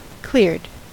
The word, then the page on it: cleared: American English